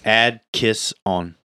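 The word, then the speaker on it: Adkisson: Atkinson? – Yeah. It's Ad Kiss On.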